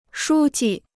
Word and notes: 书记: shū ji

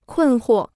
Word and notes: 困惑: kùn huò

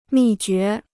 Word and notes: 秘诀: mì jué

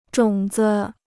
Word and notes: 种子: zhǒng zi

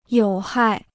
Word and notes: 有害: yǒu hài

